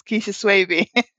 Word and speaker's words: Keisha Swaby: And just to correct, it's Keisha Swaby.